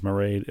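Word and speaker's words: Mairéad: Her name is Maraid. It rhymes with parade.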